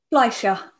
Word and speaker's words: Fleischer: Fleischer, like a fly.